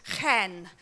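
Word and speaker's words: chen (חן): And the word they use in the Hebrew for grace is chen. I've been practicing my Hebrew pronunciation. You have to cough, basically, when you say it.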